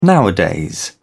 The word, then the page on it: nowadays: British English